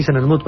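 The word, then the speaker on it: Senenmut: Senesmut. Yo no sé si lo digo bien. – Sí, Senesmut, perfectamente. Es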